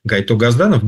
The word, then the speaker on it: Гайто Газданов: ну, правильно так его, да, произносим имя, Гайто Газданов, Георгий Иванович Газданов.